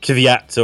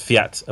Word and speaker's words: Kvyat: It's not it's not Kvyat or anything like that. It's Fiat apparently. So there's just a silent K. Okay. – yep. Uh, well, it's a silent K that's pronounced as an F, I think.